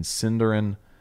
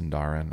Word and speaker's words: Sindarin: All we have to decide is what to do with the time that is given to us in Sindarin, Elvish of my left forearm. Mm. Wow. I think it's actually pronounced Sindarin. I always mess that up.